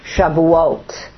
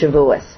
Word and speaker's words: Shavuot: In synagogues, the book of Ruth is read during the feast of Shavuot, which some Jewish people pronounce Shavuos.